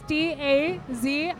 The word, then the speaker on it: Dazie: Social media, my name is Dazie. I spell it very odd, but it's Dazie.